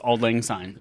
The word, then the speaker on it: Auld Lang Syne: But also, yes, that song I was singing is Auld Lang Syne, if you look up the lyrics.